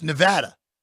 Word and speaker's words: Nevada: He was the first person, Professor Robert Smale, who was like, oh, it's Nevada. We're all the 19-year-old kids in Missouri. Like, what are you talking about? It's not. – You must say Nevada. – It is absolutely Nevada.